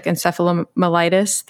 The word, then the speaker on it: encephalomyelitis: Myalgic encephalomyelitis, the fancy word for chronic fatigue? – Encephalomyelitis.